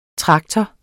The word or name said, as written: traktor